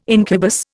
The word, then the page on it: incubus: Indian